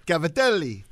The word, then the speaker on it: Cavatelli: Cavatelli.